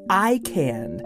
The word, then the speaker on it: ICAN: Sarah Zwinklis works for All Classical Portland, and she helped start up and is now the main host of ICANN, the International Children's Arts Network.